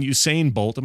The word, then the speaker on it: Usain Bolt: Running robot aims to take on Usain Bolt.